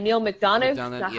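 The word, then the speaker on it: Neal McDonough: Neil McDonough? – Yes.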